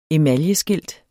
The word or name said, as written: emaljeskilt